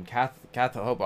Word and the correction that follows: Kath Lobo: I mean, I would, yeah, Kath Lobo actually is how you pronounce her name.